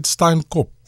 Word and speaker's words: Steinkop: Have you been to, how do you say it, Steinkop? – Steinkop. – And the F, is it silent? – I'm ignoring the F.